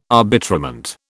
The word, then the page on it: arbitrament: British English